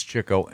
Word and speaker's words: Chico: Chico, it's pronounced Chico, really. – Spelled Chico. – I've never heard that one. – It's spelled Chico, but it's Chico.